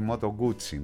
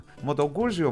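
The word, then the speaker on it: Moto Guzzi: Η Μοτογκούτσι, ναι. Μοτογκούζι όμως το λέγαμε εμείς παλιά που δεν ξέραμε τη σωστή προφορά, όταν τα καβαλάγαμε τα διχρονάκια αυτά τα ωραία, μετά έβγαλε και μεγαλύτερες μοτοσυκλέτες.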